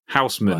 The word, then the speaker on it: Huisemoen: His name was Huisteen Huseman. Houseman. Right. It's spelled Huisemoen guitars on Instagram.